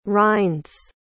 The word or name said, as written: rinds